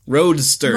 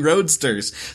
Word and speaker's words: Roadsters: Okay, it's Luigi's Rollicking Roasters. Roadsters. – Roadsters. – I sound like I said roasters, but it's Luigi's Rollicking Roadsters.